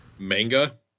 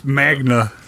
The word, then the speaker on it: manga: Are they manga? – No, they're light novels, I think, technically. – I believe it's Magna. – Yes, Magna. I'm sorry. – Manga. – We have to use the Japanese pronunciation, Magna.